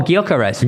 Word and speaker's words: Gyökeres: I don't know how to pronounce him probably, but Gyökeres. – Oh, Gyökeres.